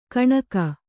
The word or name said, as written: ಕಣ್ಗಾ